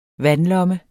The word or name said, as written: vandlomme